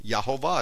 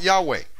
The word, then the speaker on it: Yehovah: Because we know how to actually spell the name of Yehovah. It's not just the Tetragrammaton. It's Y-E-H-O-V-A-H. It's not Yahweh. There is no W in God's name.